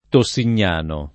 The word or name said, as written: Tossignano